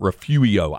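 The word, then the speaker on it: Refugio: Somebody gave me a pronouncer and Refurio went Refuio. I've never, ever, ever, ever, ever, ever heard that. – It's a silent R.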